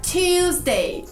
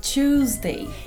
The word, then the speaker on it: Tuesday: E tem mais uma diferença de pronúncia, por exemplo, na palavra terça-feira. Tuesday é a forma que eu diria. E eles diriam? Tuesday. Então aqui, o seu T não precisa ser claro. Pode ser tu. Tuesday.